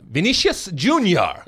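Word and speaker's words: Vinícius Júnior: Number 9, Vinicius Junior. – Yeah. – Am I pronouncing that even close to correctly? – Yeah, that's right.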